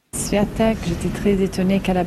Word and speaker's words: Świątek: L'américaine Sofia Kenin, sixième joueuse mondiale, affronte la polonaise Iga Świątek. J'espère que ça se prononce comme ça. – Świątek. – Bravo, Eric.